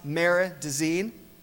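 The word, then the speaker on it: Marais des Cygnes: And something that my wife noticed was that this is a hard-to-pronounce name of a river if you don't know French. It's the Mare de Seine, right? But it is not spelled like that. So if you want to remember how to pronounce Mare de Seine, remember, it rhymes with otter latrine.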